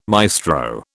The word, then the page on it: maestro: British English